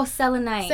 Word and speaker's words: selenite: Oh, selenite.